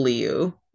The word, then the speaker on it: Liu: Is it Lau? – Simu Liu.